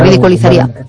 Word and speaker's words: ridiculizaría: Ridiculizaría. Sí. Es difícil de pronunciar. Piensa siempre en la palabra inicial. Ridículo. Entonces, ridiculí sería. Cuando una palabra se te revela, es mejor dividirla en dos. Entonces, primero tienes la primera parte, pues eso, ridiculí, porque te suena.